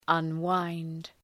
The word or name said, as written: unwind